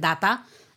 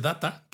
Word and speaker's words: Data: Porque en español, claro, dices data, que es como un eh, estadounidense pronunciaría también, más o menos, ¿no? Eh, la palabra data, pero al, personaje lo llaman siempre en la serie, cuando la escuchas en versión original, al estilo británico, dicen Data.